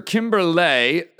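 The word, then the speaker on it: Kimberlé: The Professor Kimberley, there's an accent on the end of that. Got to catch that.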